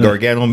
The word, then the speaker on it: Gargano: I say his name right? – Yeah, you said the right, Johnny Gargano, Mr. Johnny Wrestling to the rest of y'all.